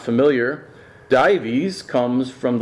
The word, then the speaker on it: Dives: The rich man was dives in Latin, thus Dives became the way to pronounce it in English.